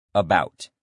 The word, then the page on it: about: male